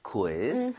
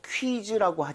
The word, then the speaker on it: quiz: 아, 그나저나 읽을 때 이거 퀴즈라고 하지 마시고요. 우이를 따로 다 발음해야 돼요. – quiz. – quiz. 그 대신에 아. – 빠르게 하는 것 뿐이에요. So, quiz 아니라 quiz. quiz. 좀 이런 식으로 퀴 말고요.